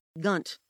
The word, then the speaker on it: Gunt: It's a great brand, Gunt, and it rhymes with runt.